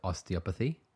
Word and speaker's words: osteopathy: The osteopathy.